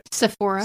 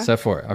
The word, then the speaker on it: Sephora: Sephora. – Sephora, okay, yes.